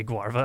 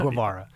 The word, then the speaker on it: Guevara: I forgot his name was Che Guarva, and I almost called him Cliche Guarva. – Guevara.